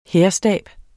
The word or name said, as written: hærstab